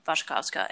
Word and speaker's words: Wasikowska: HBO Max also currently has the 2011 adaptation with Mia Wasikowska and Michael Fassbender. So have I been saying her name incorrectly this whole time? – The W's are pronounced like B's.